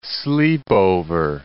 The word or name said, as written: sleepover